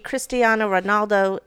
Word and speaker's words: Cristiano Ronaldo: A Cristiano Ronaldo, am I even saying his name right? Probably not. – Yeah, I think you are.